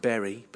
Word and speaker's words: Bury: It's pronounced Bury, but it's spelt B-U-R-Y.